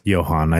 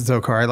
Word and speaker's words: Dzhokhar: The youngest brother, Zokar, his name is spelled D-Z-H-O-K-H-A-R. – And it's actually pronounced Johan. I just didn't want to correct you. Okay. It is. It's Johan. – So Johan. – No, it's not. That's Zokar. Like I watch college students that were friends.